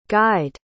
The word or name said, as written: guyed